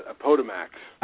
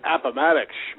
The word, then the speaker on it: Appomattox: They mispronounce it as a Potomac. – A Potomac, that's right. – Potomac. And someone goes, Appomattox, schmuck.